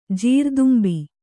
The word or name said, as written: ಜೀರುದುಂಬಿ